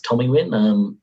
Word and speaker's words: Tomewin: and then it finishes out at the climb at Tomiwin, which is spelled T-O-M-E-W-I-N, but I've been reliably told it's Tomiwin.